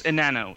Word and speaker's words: Enano: It's Enano. It's Spanish. It means small, midget, whatever.